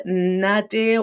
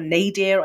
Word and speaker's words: nadir: it's either nadir or nadir. I don't know how we pronounce it in different countries. It's spelled N-A-D-I-R, and it's basically the peak.